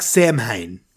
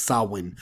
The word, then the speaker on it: Samhain: Which is, which looks like Samhain, right? That's what it looks like pronounced, but it's actually pronounced Samhain.